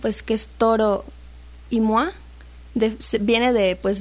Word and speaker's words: Moi: Bueno, Moi, se escribe Moi, pero se pronuncia Moa, de su disco Bubu.